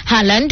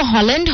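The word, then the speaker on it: Haaland: মানে খুব ভাইরাল একজন প্লেয়ার হালান্ড, যার উচ্চারণ যদিও হল্যান্ড হয় বাট অনেকেই হালান্ড বলে।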